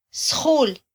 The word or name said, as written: sjul